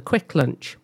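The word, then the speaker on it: Kvikk Lunsj: And the problem is that there is another chocolate treat in Norway called the Quick Lunch. Apparently that's how you pronounce this thing with lots of K's, V's, K's and U's. Quick Fluncy, it looks like, but Quick Lunch in Norway.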